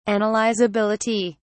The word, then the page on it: analysability: British English